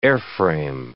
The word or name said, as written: airframe